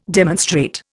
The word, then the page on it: demonstrate: Indian